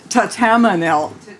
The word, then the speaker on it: Thatamanil: Tatamanil. – T-H-A-T-A-M-I-N-I-L. – Yep. – T-H-A-T-A-M-A-N-I-L.